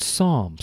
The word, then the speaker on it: psalm: So let's give a brief definition of the word psalm. Psalm is spelled P-S-A-L-M, psalm.